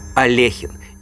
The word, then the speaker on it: Алехин: Алехин. Именно так правильно произносится фамилия единственного в мире шахматиста, который умер, являясь действующим чемпионом мира.